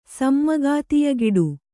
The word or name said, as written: ಸಮ್ಮಗಾತಿಯಗಿಡು